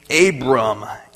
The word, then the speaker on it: Abram: Tonight we're going to be looking at a guy named Abram. A-B-R-A-M. He gets his name changed later. Yes, I'm not saying it wrong.